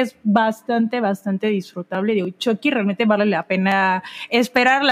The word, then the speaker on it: Chucky: Exacto, y Miranda nos está acompañando como cuarto integrante, Chucky, así es como se pronuncia, según el estudio. Chucky.